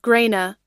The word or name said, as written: grana